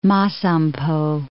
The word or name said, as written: Masampo